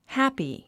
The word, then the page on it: happy: hǽpi; ハッピー